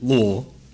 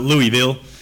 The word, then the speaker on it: Louisville: And you know you've lived there if you pronounce it Louisville, not Louisville, like other Texans especially say it.